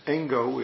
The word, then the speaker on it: Engo: I don't know exactly, because there's questions about its exact origin, but let us say about a century plus later, Yuan Wu, also known as Engo in Japanese way of pronouncing it,